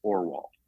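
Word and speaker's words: Orual: And so the whole story is told through the mouth of the ugly eldest sister, whose name I pronounce Orwal.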